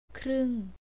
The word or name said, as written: kreung